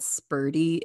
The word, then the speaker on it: Sperti: But the brand of vitamin D sun lamp that I was thinking of is called Spurti. It's spelled Sperti.